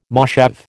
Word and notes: moshav: American English

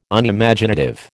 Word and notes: Unimaginative: American English

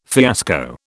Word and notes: fiasco: British English

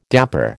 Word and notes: dapper: American English

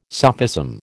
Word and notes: sophism: American English

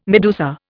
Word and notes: medusa: Indian

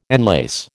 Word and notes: enlace: American English

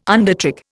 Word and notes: undertrick: Indian

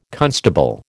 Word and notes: constable: American English

